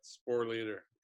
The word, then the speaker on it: Sporleder: Yep, Sporleder.